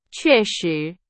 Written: 确实

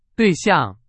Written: 对象

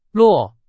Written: 落

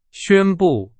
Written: 宣布

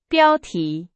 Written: 标题

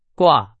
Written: 挂

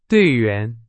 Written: 队员

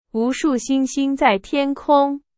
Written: 无数

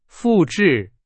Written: 复制

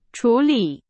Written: 处理

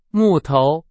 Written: 木头

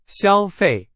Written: 消费

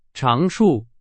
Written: 长处

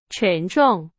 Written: 沉重

